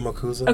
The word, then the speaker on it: Marcuse: Is it Marcuse? – Herbert Marcuse. – Okay, Marcuse. – His children and grandchildren, and his son and grandchildren now use Marcuse.